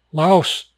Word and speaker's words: Laos: Laos, pronounced locally as Lao.